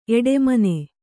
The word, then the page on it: ಎಡೆಮನೆ: eḍemane